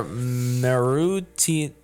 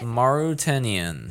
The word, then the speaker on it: Mauritanian: Mauritanian.